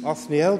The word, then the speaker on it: Othniel: I tried to find out what his name means, but nobody seems to know what his name means, Othniel, but I'm quite sure that's how it's pronounced,